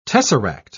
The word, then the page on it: tesseract: British English